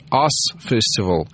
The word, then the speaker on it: US Festival: Это фестиваль, который называется Us Festival, так он правильно читается, который проходит в Калифорнии.